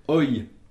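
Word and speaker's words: oj: Par exemple, le pluriel d'un nom, d'un substantif, se prononcera donc OI. Ça s'écrira O-J et se prononcera OI.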